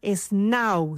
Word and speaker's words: Snaau: Hay una startup colombiana que se llama Snau, se escribe S-N-A-A-U.